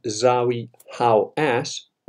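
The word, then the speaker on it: Zahi Hawass: And he mentioned this, he, he made it public, he made people know about it, and of course Zawi How Ass, that is the correct pronunciation of his stupid Egyptian name,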